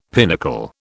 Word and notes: pinnacle: British English

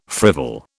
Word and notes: frivol: British English

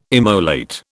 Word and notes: immolate: British English

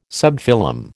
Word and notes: subphylum: American English

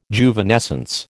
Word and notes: Juvenescence: American English

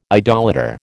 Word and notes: idolater: American English

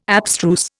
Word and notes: abstruse: Indian

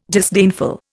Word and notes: disdainful: Indian

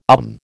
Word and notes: um: American English

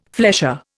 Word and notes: flesher: Indian

